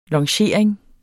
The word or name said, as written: longering